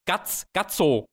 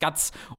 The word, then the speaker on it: Guts: Und das ist so, als ob du, das sind wie diese Fansubs, die Gatso genannt haben. G-A-T-S-O. Weil im Japanischen halt so genannt wird, aber nee, sein Name ist Guts.